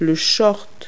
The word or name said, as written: shorts